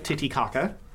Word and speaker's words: Titicaca: As we drive up into the Andes, and that'll bring us to Puno, which is right near Lake Titicaca. – Is that the actual pronunciation? – Yes.